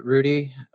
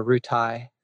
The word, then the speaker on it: Rutie: And it's spelled like Ru and Tie, like R-U-T-I-E. Uh-huh. And so you can call it Rudy or Rutai.